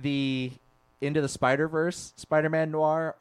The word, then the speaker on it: noir: Is it Noray? Nora? – Noir. – Noir. There we go.